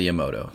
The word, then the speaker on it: Yamato: the Yamato. Did I say that right? – Yamato, thank you.